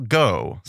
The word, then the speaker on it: Goh: And so Raphael here was played by Michael Goh, spelled like the last part of Van Gogh. – All right. Not like the formerly known G-O. – Yeah. Not like the verb go.